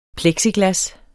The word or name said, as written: plexiglas